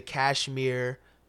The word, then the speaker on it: KSHMR: So if you're a Game of Thrones fan, tap into the Kashmir Game of Thrones remix. His name is spelled K-S-H-M-R, no vowels.